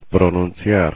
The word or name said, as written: pronunciar